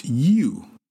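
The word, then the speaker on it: yu (鱼): Fish in Mandarin is yu, Y-U. This is the same pronunciation as leftovers or leftover.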